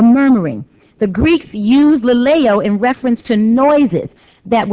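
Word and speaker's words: laleo (λαλέω): One is pronounced laleo, L-A-L-E-O, and the other is pronounced lego, L-E-G-O. In our text here, the Greek verb for speak is laleo, L-A-L-E-O.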